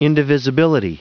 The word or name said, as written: indivisibility